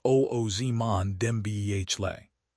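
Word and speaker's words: Dembélé: Managers are now forced to sacrifice defensive depth to afford premium assets like Harry Kane, pronounced Kane, or Ousmane Dembele, pronounced Oozman Dembele.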